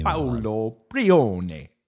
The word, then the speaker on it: Paolo Pione: And we got some good feedback from some of the folks, like our good friend Paolo Pione. I didn't say that right. Sorry, Paolo. I'm an American. – He won't care because you're saying his name. – Paolo Pione. There we go.